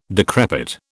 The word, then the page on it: decrepit: British English